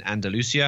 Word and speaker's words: Andalusia: So their most populous region held an election this week that was in Andalusia. I was toying with trying to avoid saying it so I wouldn't have to butcher the pronunciation. – It sounded good. I think you're okay.